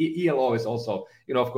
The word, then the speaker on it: Elo: So Elo, it means if we pronounce it Elo, it means life in Finnish. And if you pronounce it Ilo, as some people might, it means happiness.